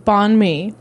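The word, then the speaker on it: bánh mì: B-A-N-H-M-I. I would pronounce it bon mi.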